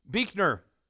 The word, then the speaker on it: Buechner: Buechner, which again, B-U-E-C-H-N-E-R. The entry goes this way. Buechner, it is my name. It is pronounced Buechner. If somebody mispronounces it in some foolish way, I have the feeling that what's foolish is me.